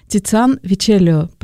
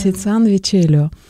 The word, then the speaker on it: Тициан Вечеллио: И это просто могучий, необычайно талантливый, глубокий художник – Тициан Вечеллио. Правильно я делаю ударение, да? – Да, правильно. – Тициан Вечеллио.